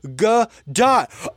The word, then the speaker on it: Gadot: The T on her last name isn't silent. It's pronounced G dot.